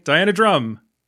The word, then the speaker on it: Diana Drumm: Diana Drumm.